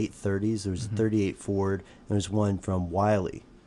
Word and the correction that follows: Willys: Am I saying that right? – I think it's Willys. – Willys. It is Willys.